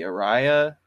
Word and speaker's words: Ariya: And then Nissan's supposed to announce the Ariya, if that's how you say it, A-R-I-Y-A.